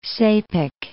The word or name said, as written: Sepik